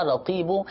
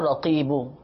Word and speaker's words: رقیب: یا رقیب مدنی چینل کے ناظرین, یا رقیب, دیکھیں تلفظ اس کا سماعت فرما لیجئے, یا رقیب, یا ر قاف, اس میں قاف ہے موٹا والا, قاف پھر یا پھر با,